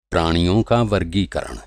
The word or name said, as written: वर्गीकरण